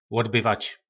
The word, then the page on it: lodbyvac: po našimu